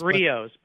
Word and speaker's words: Griot's: Well, just to let the audience know, it's how do you pronounce that? Because it looks like it's Griots. – Well, it's Griots. – Griots, so G-R-I-O-T-S.